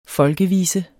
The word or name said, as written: FOLKEVISEN